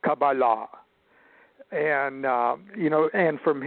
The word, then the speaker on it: Qabalah: He said, "You don't even call it, you don't even pronounce the word right. It's Qabalah, Qabalah."